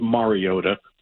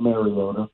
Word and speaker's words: Mariota: You know, you did say Mariota sucks, and by the way, it's pronounced Mariota, not Mariota.